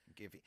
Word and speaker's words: Giphy: And so it has, is it pronounced Giphy? G-I-P-H? Giphy.